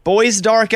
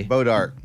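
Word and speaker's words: Bois d'Arc: And hello to Boys Dark Elementary. – Bodark. – But Bodark. – It's spelled... – It's pronounced Bodark, but it's spelled Boys Dark.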